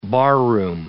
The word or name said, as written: barroom